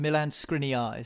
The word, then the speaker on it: Milan Skriniar: Milan Skriniar is how you say it. Correct, Jim. Well done.